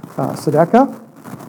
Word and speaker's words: tzedakah: Let me spell it for you. You ready? They're T-Z-E-D-A-K-A-H. The tzedakah.